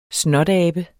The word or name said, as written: snotabe